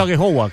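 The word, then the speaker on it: Aghehowa: No sé si lo he pronunciado bien, pero... he dicho a Gehowa, ¿cómo se pronuncia? – Sí, bien, la verdad que...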